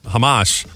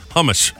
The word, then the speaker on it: Hamas: No, I think he just pronounced it wrong. You know, he changed the emphasis on the A. So instead of hamas, he said, oh, the hummus is bad.